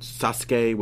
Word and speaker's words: Sasuke: That's like when they had Great Sasuke, but they'd call him Sasuke, which is the correct pronunciation.